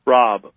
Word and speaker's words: Raub: Am I saying his name right? – Rob. Brandon Raub.